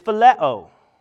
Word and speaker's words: phileo: This one is phileo, is how you say it.